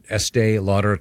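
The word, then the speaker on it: Estée Lauder: Is it pronounced Estee Lauder? – Estee Lauder, yes.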